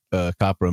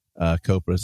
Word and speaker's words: COPRA: Speaking of movies, if they were to make a COPRA movie, COPRA, sorry. See, I've been saying it right in my head the whole time. And then I just fucked it up.